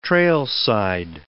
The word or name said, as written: trailside